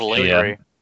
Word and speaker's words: lingerie: Someone came in with like, you know, was wearing lingerie and let Matt pick the dress that she was going to wear. – I think that was Kaylee. – Pronounced lingerie, by the way.